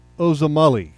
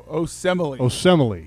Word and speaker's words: Osemele: And because I cannot be disrespectful to the offensive lineman, okay, the left guard for the Baltimore Ravens, it's Kelechi Osemele. – No, Osemele. There you go, Osemele.